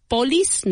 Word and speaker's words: police: Police, no. Police. Police. Emphasis on the second syllable.